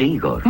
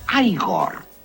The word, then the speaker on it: Igor: Usted debe de ser Igor. – No, se pronuncia Igor. – A mí me dijeron que era Igor.